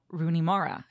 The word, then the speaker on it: Rooney Mara: And he was talking about Rooney Mara, who I think I just said her name right for the first time in living history. – It is.